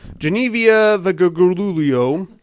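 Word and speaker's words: Genevieve Bujold: That's a correct pronunciation, by the way. I Googled it. It's Genevieve Vabruglio, Michael Douglas and Elizabeth Ashley.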